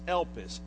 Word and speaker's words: elpis: And that little word hope in the New Testament we pronounce is elpis, E-L-P-I-S.